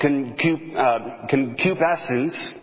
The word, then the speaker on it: concupiscence: That word is called concupiscence. I don't know if I'm even saying that right. Concupiscence, that's the correct pronunciation.